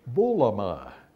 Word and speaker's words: boulomai: What is involved in these statistics is ultimately a disagreement over the meaning of the Greek word bulama. Now, if you want to write that down, you can spell it B-O-U-L-O-M-A-I